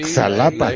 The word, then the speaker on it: Xalapa: Con todo y que un gobernador que tuvimos en los 70 le cambió y antes se escribía con J, Jalapa, y ahora se escribe con X. – Sí, Xalapa. La X y la J.